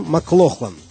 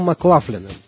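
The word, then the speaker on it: McLaughlin: В начале 60-х, когда молодой гитарист переехал в Лондон, его фамилию все еще произносили по-шотландски – Джон Маклохлан. Но в 69-м году он перебрался в Нью-Йорк. И с тех пор, как он сам с усмешкой говорит, стал Маклафленом. Так произносили его фамилию американцы.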